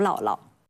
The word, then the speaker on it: 姥姥: 就像《红楼梦》当中写刘姥姥的时候，有的版本用了女字旁的刘姥姥，而另一些版本呢则用了不加女字旁，就是年老的老这两个字重叠为刘姥姥。那显然他们在这个时候可以互相替换，那它读音就应该是相同的了。那么为什么这个字会从母的读音变到老的读音呢？